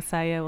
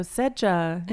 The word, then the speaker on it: Seja: And, I mean, my name, because it's spelled S-E-J-A, gets pronounced wrong a lot. So people, instead of saying Saya, will say, like, so, Sedja, you've got a new album coming out, and I immediately feel deflated.